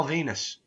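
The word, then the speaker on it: Venis: So many, like, I never got Val Venus, the fact that it rhymes with penis.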